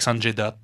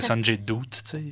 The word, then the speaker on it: Sanjay Dutt: C'était vraiment avec Sanjay Dutt, puis... – Ouais que j'ai de la misère à prononcer. Je pensais que c'était Sanjay Doot. Moi, j'ai toujours dit Sanjay Doot, étrangement.